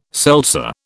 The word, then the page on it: seltzer: British English